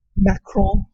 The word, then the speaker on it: Macron: I may be saying that wrong. – No, that's pretty good. – Because I don't have a French accent. – Yeah, but, that guy. – Macron. There we go.